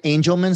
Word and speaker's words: Angelman: She has, am I saying this right, Angelman syndrome, I believe? – That's right.